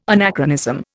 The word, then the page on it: anachronism: Indian